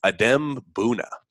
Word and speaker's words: Adem Bona: But I believe it's Adem Bona is how we officially pronounce his name. It's easy to pronounce his name, Adem Bona, but we'll try to say Adem Bona as his official name.